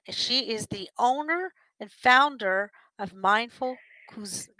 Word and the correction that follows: Cuisine: It's Mindful Cuisine.